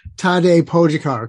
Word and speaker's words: Tadej Pogačar: But so Tadej Pogačar. Chris, how'd I do? – You did pretty good.